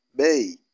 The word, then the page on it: bei: Kiswahili